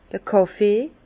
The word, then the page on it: d'r Kàffee: Alsatian; Bas-Rhin; Herrlisheim